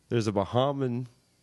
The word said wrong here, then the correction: Bahamian: is that how you say it? Bahaman? Bahaman broadcaster out there. – Bahamian.